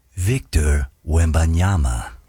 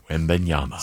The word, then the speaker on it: Wembanyama: Victor Wembanyama. – Wembanyama.